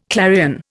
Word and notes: clarion: Indian

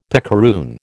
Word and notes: picaroon: American English

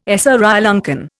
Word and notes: Sri Lankan: Indian

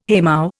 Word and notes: haymow: Indian

